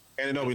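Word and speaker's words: Anunoby: Is it Ananobi? Is that how you say his name? – Ananobi.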